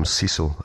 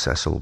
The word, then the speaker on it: Cecil: I think it was Sir William Cecil, I think, remember, often pronounced Cecil, but he was a guy who was first given in charge of starting this whole spy network up.